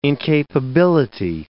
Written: incapability

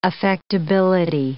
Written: affectability

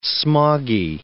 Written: smoggy

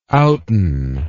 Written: outen

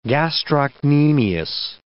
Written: gastrocnemius

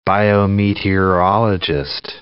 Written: biometeorologist

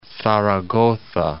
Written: Zaragoza

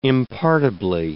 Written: impartibly

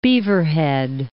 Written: Beaverhead Mountains

